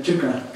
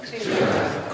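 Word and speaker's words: ṭīkā: say chika. – Chika. – Curl your tongue back, retroflex your tongue, chika.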